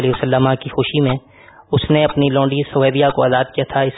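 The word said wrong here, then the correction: ثویبہ: اس کا صحیح تلفظ ہے سویبا لونڈی کو اس نے آزاد کیا.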